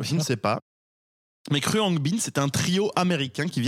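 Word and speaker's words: Khruangbin: Mon coup de cœur est pour un groupe de musique qui s'appelle Kryongbin. – Kryongbin.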